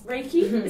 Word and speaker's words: Reiki: Maybe the solution is Reiki. Is that how you say it? – Yes. Maybe it is Reiki, I don't know.